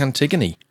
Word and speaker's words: Antigone: It is Antigone. I was right the first time. If you'd like to find out more about Antigone Rising, now that we know that's how it's pronounced,